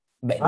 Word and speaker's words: Venmo: Venmo.